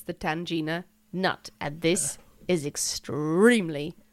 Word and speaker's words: tangena: Now, I believe it's pronounced Tangina. T-A-N-G-E-N-A. So the Tangina, do you know what it is? – No, I can't say I do.